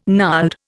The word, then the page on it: gnarled: Indian